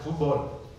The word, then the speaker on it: fútbol: We went out to play like real soccer, fútbol, with an accent on the U.